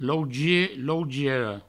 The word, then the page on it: lougier, lougiera: provençal; lougie, lougiero